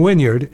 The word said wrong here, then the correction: Wynyard: instead of Wynyard. Yard. Yard. I mean, and it makes sense. It's spelled W-Y-N-Y-A-R-D. I don't say I was playing in the backyard yesterday. I don't say that. I'm pretty sure I don't. But for some reason, I say Wynyard when it's pronounced Wynyard.